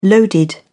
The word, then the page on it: loaded: British English